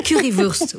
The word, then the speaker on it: Currywurst: Oui, la Currywurst. – C'est Currywurst. Oui, c'est ça, c'est vrai. C'est Wurst. Oui, c'est, voilà. – J'ai dit Wurst.